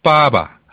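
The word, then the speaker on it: 빠바 (爸爸): 자, 빠바. 할때 뒤에 바는 그냥 툭 예, 가볍게 예, 이렇게 됩니다.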